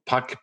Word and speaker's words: Puck: Michael Puck, P-U-C-K. And my handle on LinkedIn is Puck N-U-S-A.